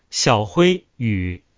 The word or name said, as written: pronounce